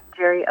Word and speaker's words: Jeri: Jeri Ellsworth. It's a really original YouTube name. And that's, my name is spelled Jeri, J-E-R-I.